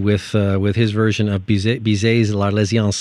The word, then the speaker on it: L'Arlésienne: This is Bizet, L'Arlésienne. My French is not very good. I don't know if I'm pronouncing that even close. – Yeah, you got it right.